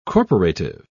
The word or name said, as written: corporative